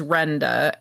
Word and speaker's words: Renda: My legal name is Renda, and Pete, and I have a British friend that calls me Render, and it drives me nuts.